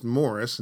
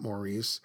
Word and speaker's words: Maurice: And quickly, if I might point out, his first name is pronounced Morris, not Maurice. I always made the mistake myself of saying Maurice.